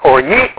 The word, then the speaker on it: orge: Orge, or for those of you who are in the Greek class, or ye, or gay, depending on which pronunciation system.